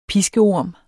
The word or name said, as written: piskeorm